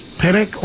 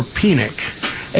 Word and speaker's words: Penick: And Harvey, now I've heard it pronounced both ways, Penick or Penick, and he of course, the author of the Little Red Book.